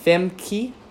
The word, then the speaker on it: Femke: That's Femke. F-E-M-K-E. Femke. Halsema. H-A-L-S-E-M-A.